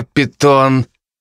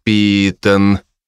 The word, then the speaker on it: Питон: Ударение на первый слог, а не на второй. «Надо произносить «Мистер Питон», — терпеливо поправил я его, но с удовольствием съездил бы по шее.